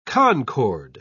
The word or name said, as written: concord